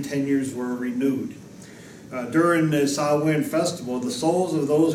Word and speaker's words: Samhain: Now, let me spell out Samhain for you, since it's a Gaelic word that's pronounced Samhain. It's spelled S-A-M-H-A-I-N. S-A-M-H-A-I-N. I would say Samhain, but it's a Gaelic word, and so it's actually pronounced Samhain. Now, Samhain was among the Celtics of Britain and Ireland, where they honored this god, the Celtics there.